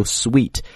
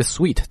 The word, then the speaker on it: suite: 所以呢这个 presidential 也是从这个总统这个词过来的，总统的啊，比如说住酒店的时候我们能听到一个词叫 presidential suite, 我们把它翻译成叫总统套房啊，讲这个 suite 它也是一个变音词了啊， suite, 不要读 suit, 它发音呢是个法语词，跟那个糖果那个单词一样 ,sweet, presidential suite, OK。